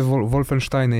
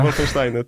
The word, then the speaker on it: Wolfensteiny: No to jakby, ja w ogóle, dla mnie to jest w ogóle super informacja, bo akurat te Wolfensteiny, ja wolę tutaj taką niemiecką wymowę. – Tak, Wolfensteiny, tak.